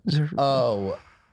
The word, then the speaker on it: Zerubbabel: The Lord rejoices to see the work begin, to see the plumb of Zerubbabel. How do you say that? – Which one? Zerubbabel.